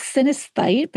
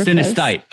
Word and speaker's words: synesthete: Number one, did I even say that correctly? And number two, what in the world does that mean? – Yeah, it's synesthete, but you were super synesthete.